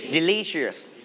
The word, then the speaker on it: delicious: Delicious. All right, so delicious, you need to stress on the second syllable. Delicious. One more time, delicious.